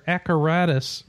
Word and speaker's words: Acaratus: Arcadus is spelled, or Acaratus rather, is spelled like apparatus but with a C. So A C A R A T U S.